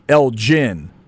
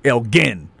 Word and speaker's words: Elgin: There's a city up in Illinois that they pronounce Elgin. But in Texas, you go hard. Elgin. – Elgin, yeah. – Right? There's no J.